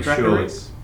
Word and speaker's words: Dracarys: I think it's pronounced Dracarys. Is that right? – Dracarys.